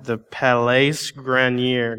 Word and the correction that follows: Garnier: The Garnier.